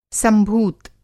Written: संभूत

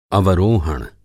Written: अवरोहण

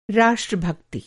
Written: राष्ट्रभक्ति

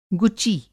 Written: गुची

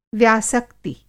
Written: व्यासक्ति

